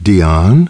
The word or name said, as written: Dion